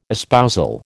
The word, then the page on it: espousal: American English